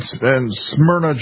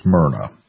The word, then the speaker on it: Smyrna: They're made in Smyrna, Georgia. Am I pronouncing that right? I thought it was Smyrna. And everybody just sort of smiled and chuckled, "Oh, you big idiot, it's Smyrna."